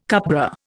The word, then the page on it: cupbearer: Indian